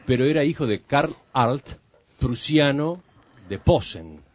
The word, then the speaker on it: Arlt: Para los oyentes más jóvenes, el apellido se escribe A-R-L-T, Arlt.